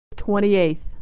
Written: 28th

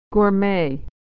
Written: gourmet

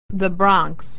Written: Bronx

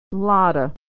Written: lotta

